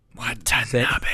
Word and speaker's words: Watanabe: Watanabe.